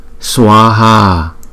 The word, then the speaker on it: Swaha: Swaha, Swaha, Swaha. Hai chữ A này đều có dấu gạch ngang trên đầu, cho nên chúng ta phải kéo dài hai nhịp là Swaha. Còn chữ A trong chữ Kari này là Kari, là chữ A này đọc là A, chứ không phải là A, không?